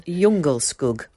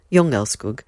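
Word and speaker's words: Djungelskog: Jungelskog. – Excellent. – Spelt de Jungelskog, but I did have a little look earlier at the pronunciation, and it's Jungelskog.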